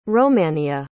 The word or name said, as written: romannea